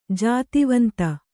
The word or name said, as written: ಜಾತಿವಂತ